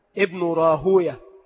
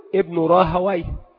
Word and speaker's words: راهويه: المحدثون ينطقونها هكذا: ابن راهوية، ولا يقولون ابن راهويه، ويقولون ويه شيطان، فكانوا يبغضون أن تنطق هكذا، وكذلك في جميع نظائرها.